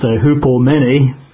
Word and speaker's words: hupomone: I think it's pronounced hupomene, H-U-P-O-M-O-N-E, hupomene or hupomone.